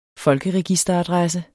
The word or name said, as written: folkeregisteradresse